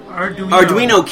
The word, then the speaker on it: Arduino: Arduino kit, which I can create to my heart's content.